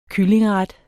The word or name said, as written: kyllingeret